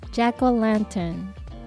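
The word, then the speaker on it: jack-o'-lantern: 英語で発音するとジャック・オランタンと言い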